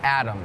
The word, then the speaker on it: atom: atom is tricky because it sounds like there's a d in it atom but it's actually A T O M But it's still pronounced Adam.